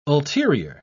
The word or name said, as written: ulterior